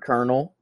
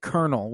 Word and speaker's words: Colonel: I hate the word Colonel. Doesn't make any sense. – No, like, how did that even, how did that happen? How is it Colonel when it's spelled COLO? How is that cur? – That's a great question.